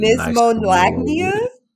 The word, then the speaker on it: knismolagnia: Because the K has to be silent. – Yeah, the K is silent. It's not Nismolagnia?